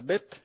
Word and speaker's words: bet: entonces vemos por ahí una letra más grande que las demás, que es la letra Bet, y se pronuncia exactamente como B, ¿no? Como una B de burro.